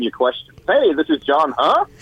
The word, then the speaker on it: John Huh: It's John Huh.